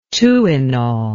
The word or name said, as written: Tuinal